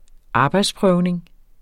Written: arbejdsprøvning

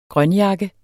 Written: grønjakke

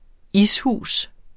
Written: ishus

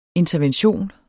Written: intervention

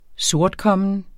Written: sortkommen

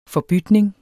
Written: forbytning

